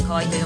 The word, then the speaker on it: 河合: さあ、そしてそんな河合コーク、河が合うと書いて河合と読むんですけれども、この名前の由来や成り立ちについて教えていただけますか。